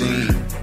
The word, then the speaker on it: zine: This is episode 30 of It's Pronounced Zine.